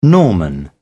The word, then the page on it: norman: British English